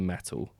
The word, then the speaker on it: Mettle: Metal. I think that's how you pronounce it. – Yeah. – M-E-T-T-L-E.